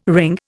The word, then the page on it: wring: Indian